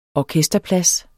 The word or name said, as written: ORKESTERPLADS